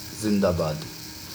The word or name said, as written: Zindabad